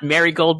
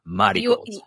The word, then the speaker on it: Marigold: So it's not Marigold, by the way, people. You got to say it with like the kind of like the katakana pronunciation, Marigold.